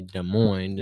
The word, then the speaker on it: Des Moines: Like, whenever people are going somewhere, like, oh, I'm going to go down to Des Moines. Like, no, that's up. Or whenever you're... – Well, the correct pronunciation would be Des Moines.